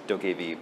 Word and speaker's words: Dokev: It's called Dokkei V. It's spelled D-O-K-E-V, pronounced Dokkei V.